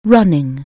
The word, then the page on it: running: anglais